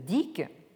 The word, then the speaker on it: Dyck: de d'Annelise Dick, ça s'écrit D-Y-C-K, euh, qui est intitulé La Chine hors de la philosophie.